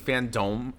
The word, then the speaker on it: FanDome: Well, because I would say it FanDome, but it's not dumb. It's pronounced dome. – Yeah. – Yeah. DC FanDome.